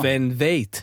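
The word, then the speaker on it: Sven Väth: Sven Veit, mm-hmm. as the Germans pronounce it, you know.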